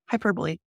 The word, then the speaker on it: hyperbole: But they spelled hyper and then B-O-L-E. – Yep, hyperbole. – Wow, I'm just dumb. Okay. – It's a weird word. It doesn't seem like it should be pronounced that way.